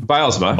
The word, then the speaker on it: Bylsma: So we'll start with chapter 1, which covers a game with Dan Bylsma. Am I saying that correctly? – Bylsma. – Close.